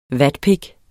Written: vatpik

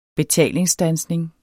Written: betalingsstandsning

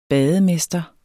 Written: bademester

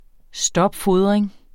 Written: stopfodring

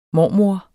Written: mormor

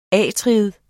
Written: atrium

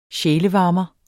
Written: sjælevarmer

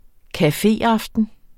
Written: cafeaften

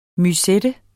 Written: musette

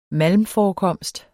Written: malmforekomst

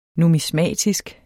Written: numismatisk

